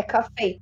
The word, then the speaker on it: Coffey: É coffee. Mas você fala coffee, né? Igual.